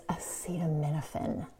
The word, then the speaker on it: acetaminophen: And one more thing, it's acetaminophen. That's how you say it.